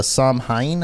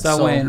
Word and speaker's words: Samhain: People believe that, but it actually started as a Celtic festival known as the Samhain, I think is it, which says was associated with communing with the dead. – Samhain, right? You said that. Okay. – Okay, yeah. I said it wrong. Thanks for the correction.